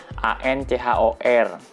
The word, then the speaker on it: Anchor: Kebetulan gue uh, pakai Android, gue download satu aplikasi namanya Anchor. Anchor ya tulisannya Ancor ya. A N C H O R. Bacanya Anchor.